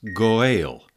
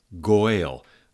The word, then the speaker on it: Goel: That word Redeemer is a translation of the Hebrew word Goel. Goel. Sometimes you see it written G-O-E-L, Goel, with two dots over the E, and sometimes it's G-A-E-L, and it's pronounced something between an O and an A, Goel, and it is a Hebrew word that appears frequently in the Mosaic Law.